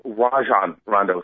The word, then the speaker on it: Rajon: Rajon Rondo, excuse me. That's how you're supposed to pronounce it.